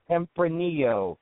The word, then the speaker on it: Tempranillo: Good, and actually that had how do you say that again, Tempranillo? – Tempranillo.